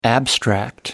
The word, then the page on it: abstract: American English